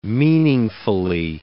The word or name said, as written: meaningfully